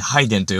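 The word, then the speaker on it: 拝殿: こう、拝む、ところと書いて、ハイデンと読むわけですけれども、えー、これをですね、えー、これをというか、まあ、ここに、実は、神様が宿っているわけではないんです。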